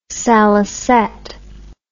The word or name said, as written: salicet